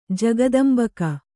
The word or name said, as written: ಜಗದಂಬಕ